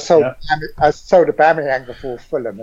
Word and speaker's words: Aubameyang: I had Monreal and Aubameyang.